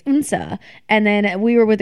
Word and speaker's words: Odense: Like oh, so it's O-D-E-N-S-E. – That's exactly what I said. – Really? – Yes. Oh. So I've been pronouncing it Odense, like the like dumb ass American that I am, and for days. And people look at me with a questionable Odense expression, like, I don't know what that is. – Yeah. – And then I start talking about it. Yeah, you know, it's the little island that's an hour and a half train ride. And they go, Unsa.